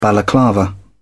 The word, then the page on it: balaclava: British English